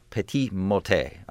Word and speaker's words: petit motet: the petit motet, I guess it would be pronounced, versus the grand motet, which is the big motet.